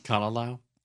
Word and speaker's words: Kalalau: Kalalau. – Kalalau Valley at the lookout.